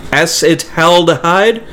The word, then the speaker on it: acetaldehyde: Acetaldehyde? I said it right.